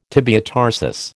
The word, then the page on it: tibiotarsus: American English